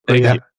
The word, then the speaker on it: Puglia: That part of, you know, we went down to, am I pronounced right? Puglia? Am I saying that right? – Puglia.